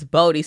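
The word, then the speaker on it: Boddie: It's the way that it's spelled. It's B O D D I E. Right? – Oh, okay. – No one ever says Bodie.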